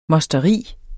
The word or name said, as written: mosteri